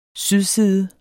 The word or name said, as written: sydside